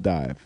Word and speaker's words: DIIV: D-I-I-V. – D-I-I-V. – Good indie rock group. – Not Div? – What? – Yeah, D-I-I-V. – Dive. Because there was already a band called Dive, spelled Dive. So they changed it to D-I-I-V. – Seems like that would pronounce Div. Div, yeah, I know. It's Dive.